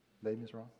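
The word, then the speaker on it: Les Misérables: Les Miserables. Is that correct? Les Miserables? Okay. Okay, all right, I got it right.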